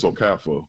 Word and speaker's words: Okafor: But the one that always kills me, Reggie, that I hate that the actual players never correct it, when people say Okafor and it's Okafor, where the R is pretty silent.